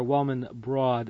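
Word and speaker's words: Wellman Braud: Wellman Broad, and I'm going to pronounce it that way, on bass.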